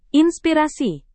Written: inspirasi

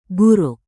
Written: buruk

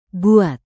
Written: buat

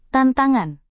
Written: tantangan

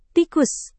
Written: tikus

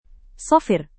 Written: safir

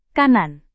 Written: kanan

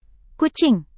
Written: kucing

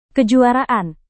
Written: kejuaraan

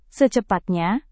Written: secepatnya

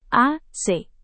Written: ac

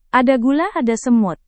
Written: ada gula ada semut

